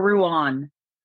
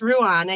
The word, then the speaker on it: Ruin: You are like Ruin. Ruin me. – Ruin. – Yeah, you are Ruin. – Me too. – A lot of people think it's Ruan.